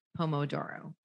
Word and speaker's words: Pomodoro: It's P-O-M-O-D-O-R-O, Pomodoro.